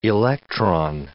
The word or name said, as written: electron